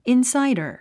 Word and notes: insider: ìnsǽidər; インサイダァ

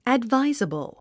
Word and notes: advisable: ədváizəbl; アドバイザボゥ

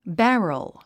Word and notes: barrel: bǽrəl; ベロォ

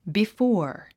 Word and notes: before: bifɔ’ː r; ビィフォア

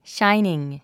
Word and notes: shining: ʃáiniŋ; シャイニン

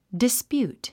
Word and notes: dispute: dispjúːt; ディスピュート

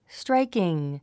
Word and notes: striking: stráikiŋ; ストライキン

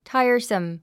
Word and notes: tiresome: táiə r səm; タァイアサム

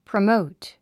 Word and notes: promote: prəmóut; プロモート

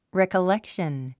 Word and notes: recollection: rèkəlékʃən; レコレクション

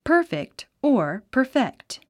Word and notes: perfect: pə’ː r fikt; パーフェクト

